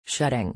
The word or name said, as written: shedding